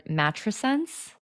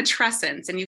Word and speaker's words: matrescence: So it's actually matrescence. – Matrescence. – Yeah. And it rhymes with adolescence. So that helps. Matrescence, adolescence.